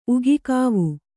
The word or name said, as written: ಉಗಿಕಾವು